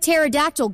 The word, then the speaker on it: pterodactyl: How come you can't hear a pterodactyl going to the bathroom? – Because the P is silent.